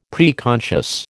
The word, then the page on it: preconscious: American English